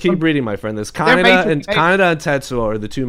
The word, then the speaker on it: Kaneda: And I'm like, Kaneda? Good God, you even pronounce it like an idiot. It's Kaneda. – So the way I said it again, yeah. – It's Kaneda.